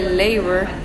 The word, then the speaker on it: Labour: Se dice Labour. – Ah, es Labour. – Sí, como hay varias formas de poder decir el apellido, pero no sé, al final del día funciona la forma en la que tú lo estás diciendo.